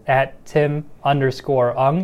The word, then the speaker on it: Ung: My handle is @Tim_Ung.